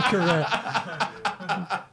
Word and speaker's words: Karangelov: Todd Karangelov.